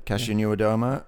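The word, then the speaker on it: Kasia Niewiadoma: Kasia Nieuwendoma. – Yeah. – Am I pronouncing that right? – I think right? – That's it, yeah. Kasia Nieuwendoma of Canyon SRAM.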